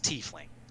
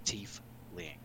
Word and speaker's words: Tiefling: And they do have a pronunciation for it, and it is Tiefling. Tiefling.